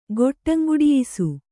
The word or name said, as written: ಗೊಟ್ಟಂಗುಡಿಯಿಸು